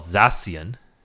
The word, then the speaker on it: Zacian: So the sword one, it's called Zacian, assuming the C-I-A-N is pronounced Shun.